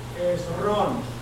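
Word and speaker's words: Hezrón: H-E-Z-R-O-N. – Esrón. – Acento en la O. Esrón engendró a Ram. – R-A-M.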